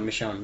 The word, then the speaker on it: Michonne: But Michonne.